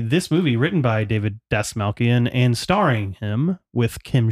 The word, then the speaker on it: Dastmalchian: Because after watching this movie, I realized that Collin Schiffli immediately followed up this movie with another collaboration with David Dastmalchian. Is that how you say his name? – Yeah, that's how I would say David Dastmalchian, who wrote this movie.